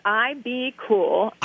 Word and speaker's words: IBKUL: Tell us about Ipcool clothing. – Sure. It's called IB Cool. – IB Cool. – Spelled, oh. – Yeah, it's spelled IBKUL.